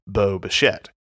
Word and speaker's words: Bo Bichette: And every time I say his name, I think of Boba Fett because it sounds like Boba Fett when you say Bo Bichette.